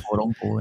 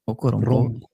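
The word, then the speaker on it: Okoronkwo: Uh, defensive end Okorwonko. Die naam heb ik waarschijnlijk verkeerd uitgesproken, dus ik ga dat niet nog eens proberen. Okoronko, ja, inderdaad. Oko... – Okoronko. – Okoronko.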